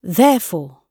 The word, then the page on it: therefore: British English